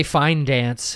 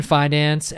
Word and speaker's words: Fidance: It's been maybe over a year, but I saw Dave Attell there before, and he had the same opener, Ian Fidance. Said it right. – Nice. – I always want to say Fine Dance for some reason. But yeah, Ian Fidance, and then Ryan, what was his last name? – Oh, we should have looked this up.